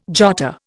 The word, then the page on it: jotter: Indian